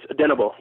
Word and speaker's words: Odenigbo: I thought it was Odenigbo, kind of pronouncing it, you know, kind of how it's spelled. But it's Odenigbo. Yeah, that's how you pronounce his last name.